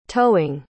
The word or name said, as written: toeing